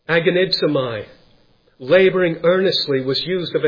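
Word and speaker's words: agonizomai (ἀγωνίζομαι): The two words there, laboring earnestly, they are really one word in the Greek language, and the word is pronounced agonizomai. We get our English word agony from it.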